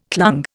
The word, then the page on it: clung: Indian